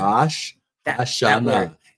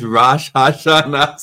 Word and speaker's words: Rosh Hashanah: How do you say it? Rosh Hashanah. Rosh Hashanah. It's not Hanukkah. – Yeah. – It's Rosh Hashanah.